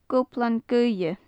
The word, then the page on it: goblan-gaoithe: gaelic